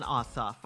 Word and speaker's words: Ossoff: We want to vote for Reverend Raphael Warnock and John Ossoff, okay? – You said it right, Senator.